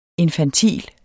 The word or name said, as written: INFANTILT